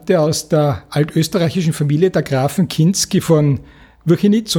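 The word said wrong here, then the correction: Wchinitz: und Tettau, wenn ich das richtig ausgesprochen habe. Kinitz und Tettau, ja. Dankeschön. Also habe ich es nicht ganz richtig ausgesprochen.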